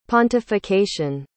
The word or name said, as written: pontification